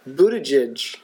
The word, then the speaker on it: Buttigieg: Buttigieg, is that how you say it? – Yeah.